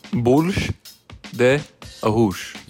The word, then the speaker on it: bolo de arroz: That's how it's spelled, but it's exactly, it's... – Bolsh de arroz.